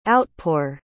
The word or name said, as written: outpour